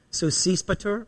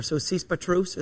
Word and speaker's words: Sosipater: Sosipater, or Sosipatrus, as it would have been pronounced in Greek.